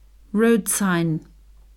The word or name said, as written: road sign